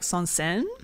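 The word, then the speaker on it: Saint-Saëns: And this song is, I think the composer's called like Sansen, is how you say it.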